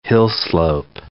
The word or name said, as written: hillslope